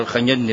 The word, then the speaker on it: الخجندي: هو على كل حال قياسا على الخجندي المتأخر يلفظ، تلفظ هذه النسبة بالفتح، لكن على كل أسماء الرجال وأنسابهم ليست خاضعة للقياس، لكن المشهور في الخجندي المتأخر هذا بالفتح لا بالضم.